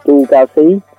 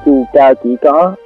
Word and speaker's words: Chu Cao Xí: Tên cũng phải đọc là Chu Cao Xí, chứ không phải Chu Cao Chỉ Có.